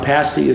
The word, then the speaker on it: pasty: Just remember, a pasty is something you eat. – Yes. – Okay. – I definitely was pronouncing it incorrectly, so I'm glad you got there before I did.